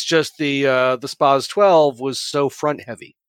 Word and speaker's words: SPAS: That's the first time I've ever heard it pronounced spaz. I've always heard it spas. – Yeah. That's because that's the right way to say it, Aaron.